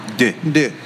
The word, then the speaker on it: deux: In case anyone's wondering, we are not mispronouncing 2. We are saying the French version of 2. – Deux.